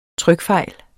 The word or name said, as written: TRYKFEJLS